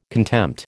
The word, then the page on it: contempt: American English